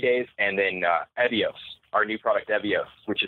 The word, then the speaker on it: Evio: It's Evios, E-V-I-O.